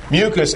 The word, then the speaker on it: mucous: Mucus, by the way, can be spelled M-U-C-O-U-S or M-U-C-U-S. They don't mean the same thing. They pronounced exactly the same. Mucus, M-U-C-U-S, is the stuff. That is the sticky stuff. Mucus, M-U-C-O-U-S, is an adjective of mucus.